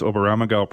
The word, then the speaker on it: Oberammergau: First of all, did I pronounce Oberammergau properly? – You did.